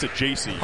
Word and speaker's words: Saggese: Him and Thomas Sajacy, asked the same thing. You'd think Sajacy would have gotten worse. – Yeah. – Because S-A-G-G-E-S-E, like, you're looking at that. Oh, how do you say that?